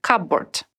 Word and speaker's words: cupboard: Отмечу, что в слове «cupboard» есть буква «p», но она не произносится. «Cupboard».